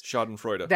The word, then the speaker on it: Schadenfreude: There's that German word that I can never pronounce, but it's Schadenfreude.